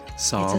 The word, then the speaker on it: psalm: It's a psalm, if you want to. – What's a psalm? – Sorry, did I not pronounce that right? Psalm? – It's a psalm.